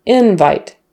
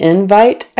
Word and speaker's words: invite: Now, on this one, again, the stress is on the first syllable for the noun, invite. Did you get the invite I sent you? The invite was in your mailbox. Invite. Stress on the first syllable. Invite. Stress on the second syllable. Verb. I'm going to invite my friends to the party. And for this word, no changes in vowels, just syllable stress. Invite versus invite. Invite noun. Invite verb.